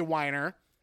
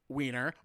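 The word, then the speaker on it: Winer: I believe it's pronounced, I'm going to say Weiner, so that it's not Wiener,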